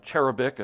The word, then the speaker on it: cherubic: like that word, cherubic is probably a better pronunciation, cherubic privilege of nearness to the throne of God.